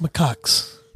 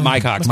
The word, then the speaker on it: macaques: By the way, it's pronounced macaques. – Macaques. Okay. – Yeah, macaques. – Macaques. – Not my cocks.